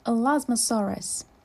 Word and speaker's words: Elasmosaurus: Elasmosaurus. Say Elasmosaurus.